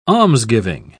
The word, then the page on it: almsgiving: British English